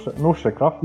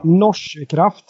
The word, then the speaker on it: Norsekraft: Ja, hur uttalar man förresten? Norsekraft? Norsekraft. Norsekraft.